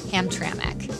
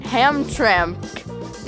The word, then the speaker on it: Hamtramck: If you're from here, you know it's pronounced Hamtramck but it can be a tough word for out of towners to get right. Here are a few of them, bless their hearts, giving it their best shot. – Hamtramck. – Hamtramck. Hamtramck. They're close. They're so close, but they're not picking up on that invisible vowel sound that us locals insert between the M and the C to get it to come out as Hamtramck.